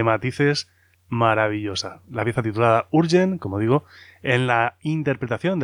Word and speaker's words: Jurjen: Pues una preciosidad esta pieza, Urgen, que se escribe con J-U-R-J-E-N.